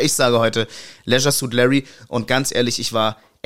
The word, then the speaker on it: Leisure Suit Larry: Aber ich habe vorhin nochmal nachgeschaut, es ist Leisure Suit Larry.